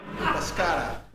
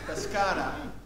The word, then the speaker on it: Pescara: Pescara. – What, sorry? – Pescara. – Pescara.